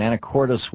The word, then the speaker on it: Anacortes: And Michael in Anacortes, Washington. Am I pronouncing that right, Michael? – Yes, yes, you are.